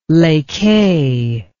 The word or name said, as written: Les Cayes